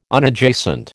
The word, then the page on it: Unadjacent: American English